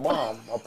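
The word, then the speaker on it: balm: Bomb, that's how you say it, right? Bomb. – Bomb. – You know, I'm from the Midwest, we say everything.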